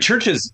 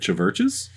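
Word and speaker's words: Chvrches: Bunch of just like, Churches is actually Churches, but it's spelled Latin. – Naturally. Yeah, correct. I know. But like, unless you'd see it and you'd be like, Chiverches?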